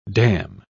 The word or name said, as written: damn